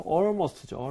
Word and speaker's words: almost: Almost가 아니라 AL. 어떻게 발음했죠? – 좀 벌려줘야지, 이번 발음. – 아, almost. Almost. 자, AL. 말고 ALL도 마찬가지예요. 발음은 어차피 우리가 all 이런 식으로 돼 있는 거잖아요. 그런 스펠링이 나오면 웬만하면 all에 가깝게 발음하는 게 좋습니다.